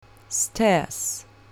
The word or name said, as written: stairs